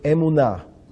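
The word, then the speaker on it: emunah (אמונה): And so we're going to look at the Hebrew word for faith or to believe, and that's the word emunah.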